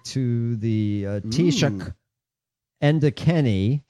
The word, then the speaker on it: Taoiseach: I know how this is pronounced, but it's in Gaelic. It's Taoiseach. – It's been that's Taoiseach. – Yes. If you look at it phonetically in English, it's Taoiseach. Taoiseach. Taoiseach.